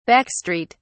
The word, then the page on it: back street: British English